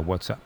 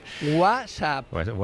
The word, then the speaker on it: WhatsApp: WhatsApp. – WhatsApp. – WhatsApp. No, peor aún, con acento en la primera.